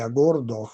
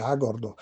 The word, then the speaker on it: Agordo: Per una persona che non abita ad Agordo, viene naturale pronunciare Agordo con l'accento sulla seconda vocale, mentre noi accentiamo la prima vocale, Agordo.